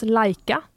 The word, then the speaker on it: Laika: eh, men det var det helt enkelt, ja, som jag sa, Simon menar då att eh, namnet uttalas Laika eh, Mm, och mm.